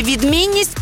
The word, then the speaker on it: відмінність: Наголос на слові «відмінність» падає на другий склад.